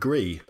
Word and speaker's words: Gris: Last night, I just finished an indie game called Gris. – Ah, with a silent S. – Yeah, because it's Spanish for grey.